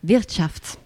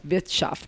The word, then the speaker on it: Wirtschaft: Ok, quindi andiamo a sentire, lo dici, lo pronunci tu il nome del pezzo, perché è un po' più... – Qual è il nome del pezzo? – Wirtschaft. – Wirtschaft. Che vuol dire economia.